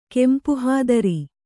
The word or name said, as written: ಕೆಂಪುಹಾದರಿ